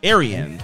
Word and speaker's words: Arienne: A-R-I-E-N-N-E. Arian.